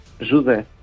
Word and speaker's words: José: Jude.